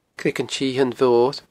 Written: Creagan t-Sithein Mhoir

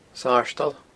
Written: sardal

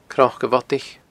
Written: cnocabhodaich

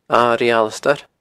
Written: Airigh Alasdair

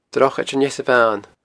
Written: Drochaid an Easabhain

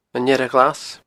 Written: Earra Ghlas

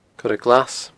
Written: coireglas

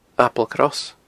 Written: Applecross

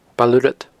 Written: Baile Urrad

